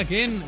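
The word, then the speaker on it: Kean: de Mois Ken, porque se pronuncia Ken.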